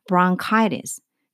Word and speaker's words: bronchitis: 그래서 brown chitis, chitis, ch가 k 발음이 나요. 치 사운드가 나지 않고, 그래서 bronchitis. 그러니까 브론은 아니고 브롱도 아니고 그 중간에 뭔가라고 생각하시면 돼요. 그래 bronchitis 하면은 기관지염이라는 뜻이 돼요.